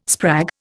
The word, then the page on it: sprag: Indian